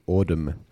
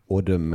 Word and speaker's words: AUDM: This is a really good app called Audum. Audum.